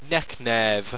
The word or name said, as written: neknerv